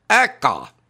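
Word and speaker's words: ACEA: Huvitavaid uudiseid veel nii palju, mis näitavad Euroopa ja eriti eurotsooni majanduskasvu, et Euroopa autotööstusliit, kui teda nüüd eestlase kombel hääldada, siis ta nimi oleks ÄKA.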